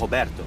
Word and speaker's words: Roberto: And when Roberto is pronounced in Portuguese, it sounds like Roberto.